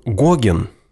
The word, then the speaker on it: Гоген: Александр Иванович фон Гоген, обратите внимание, что ударение падает на первый слог фамилии,